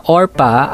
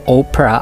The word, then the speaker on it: Orpah: Ang pangalang Orpah ay galing sa biblical figure na matatagpuan sa Book of Ruth, ngunit habang siya'y lumalaki ay laging nagkakamali sa pagbigkas ng kanyang pangalan ang mga taong nasa paligid niya na imbes na Orpah ay Oprah ang nabibigkas.